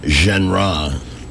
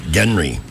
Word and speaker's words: genre: All right, I'm going to check one more day for this genre. – Yeah, I don't know where I saw it, but yeah, that's it. Because he calls it genre. – I was surprised to find out it wasn't pronounced Genry.